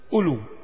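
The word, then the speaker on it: أولو: ألو ألو ألو، الواو الأولى لا تنطق عندنا ألو، لكن تكتب، والألف بعد الواو الثانية، هكذا الرسم العثماني.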